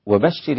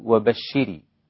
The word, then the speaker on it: وبشر: وبشر الذين آمنوا، إذا أطلت كسرة الراء في كلمة وبشر تحول الخطاب إلى المؤنث، كأنك تقول وبشري، كأنك تخاطب أنثى فتقول وبشر الذين، وبشري، لمجرد فقط أن تنطق بكسر الراء زيادة قليلا.